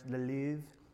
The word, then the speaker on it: Le Louvre: Is that how you say it, the French? Le Louvre. I nailed it.